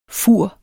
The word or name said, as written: fur